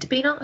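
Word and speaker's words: Debinha: So she came on as a sub, and then she basically set up a goal for, is it Dabina? I think I pronounced that properly. – Yeah.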